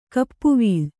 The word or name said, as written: ಗೋಪವಲ್ಲಿ